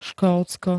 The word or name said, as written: Škótsko